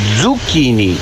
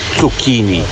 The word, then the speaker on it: zucchini: Luca, se pronunță zucchini, nu zucchini, cu doi de z.